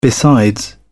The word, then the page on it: besides: British English